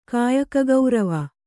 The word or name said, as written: ಕಾಯಕಗೌರವ